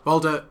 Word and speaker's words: Voldemort: People will not be able to pay attention to what you say, because they'll be like, is that Voldemort? – Voldemort. – Oh, the fancy French pronunciation.